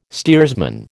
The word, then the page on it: steersman: American English